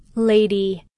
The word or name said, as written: lady